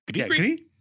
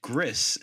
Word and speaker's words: Gris: Well, because I always say "gris," and then I heard like a video. They're like, "Oh, it's gree," and I'm like, "Or like, I must be saying it wrong too."